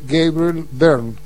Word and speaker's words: Gabriel Byrne: Vamos a ponerle Gabriel Byrne.